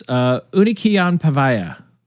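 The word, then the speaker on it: Unikeonpäivä: Which, I looked up how to pronounce this. Unikian Pavaya, I believe it is.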